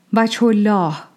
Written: Vajh-al-Allah

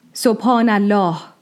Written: Subhӓna-allāh

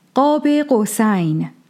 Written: Qāb-a-Qawsayn

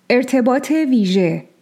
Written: Ertebāt-e Vīžeh

